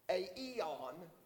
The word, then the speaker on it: aion: It's A eon is how it's pronounced. I looked that up. They've got it broke down for you. A-I-O-N, but in the Greek, A-E-on.